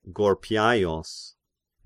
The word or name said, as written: Gorpiaios